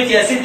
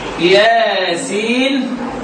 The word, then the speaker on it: ياسين: ياسين والقران. وعايز يقول لك يا سين دي بتتنطق كده، او سين ساكنه، هو النون الساكنه في الواو ادغام النون الساكنه في الواو بتاعت ياسين والقران.